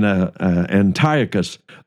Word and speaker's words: Antiochus: you may pronounce it uh, with a different emphasis, perhaps on a on a different syllable, and that would mean Antiochus IV, but either way you pronounce it, it's still the same guy.